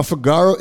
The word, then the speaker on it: Fygaro: Now, Figaro is spelled F-Y-G-A-R-O.